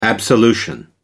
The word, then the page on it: absolution: American English